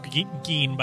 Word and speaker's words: Gein: It's pronounced Gein, by the way.